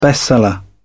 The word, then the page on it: bestseller: British English